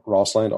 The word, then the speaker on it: Rosseland: So it's uh, Tom Rosseland, R O S S E L A N D.